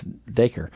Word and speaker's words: Dacre: Dacre. And it's just Acre with a D at the front. It's Dacre.